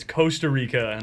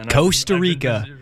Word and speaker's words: Costa Rica: I learned pretty recently it's Costa Rica. – Costa Rica. – I've been pronouncing it wrong my entire life.